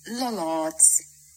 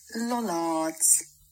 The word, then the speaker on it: Lollards: Lollards. Lollards. Now you see that was not just the proper pronunciation, but also in the beautiful accent that makes it sound so nice.